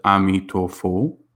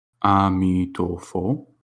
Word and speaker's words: Amitofo: And we pronounce his name in Chinese as Amitofo, Amitofo, or you can recite his name in Sanskrit, Amitabha, or Namo Amitabha, which means praise to Amitabha.